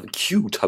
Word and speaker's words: Qt: Nein, sie mussten auf QT4 um, äh, oder Cute habe ich ja auch gehört, diese Aussprache gibt es ja auch, Cute.